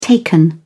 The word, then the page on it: taken: British English